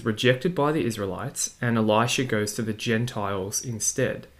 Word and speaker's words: Elisha: Some people pronounce this Elisha, just to make it a little bit clearer to compare it to Elijah, but I'm just going to call it Elisha, just for argument's sake.